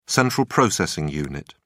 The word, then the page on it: central processing unit: British English